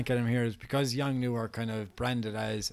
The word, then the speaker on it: Yongnuo: That's how you pronounce it. Yongnuo.